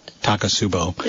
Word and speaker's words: Takotsubo: Takotsubo.